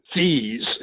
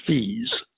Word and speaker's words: Fuess: And it was written by a man named Claude Fees, is the way his name is pronounced, F-U-E-S-S. And you see it on the page and you puzzle over how it should be pronounced, but it was Fees.